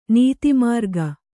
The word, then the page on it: ನೀತಿಮಾರ್ಗ: Kannada; nīti mārga